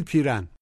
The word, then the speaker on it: پیرن: به تلفظ دقت کنید. ای نیست. یه صدایی بین او و ای. بگید پیرن.